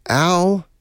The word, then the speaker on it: Aloysius: It is pronounced Al-O-Wish-Us.